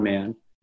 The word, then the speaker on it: Mann: and I'm on Facebook under my name Don Mann.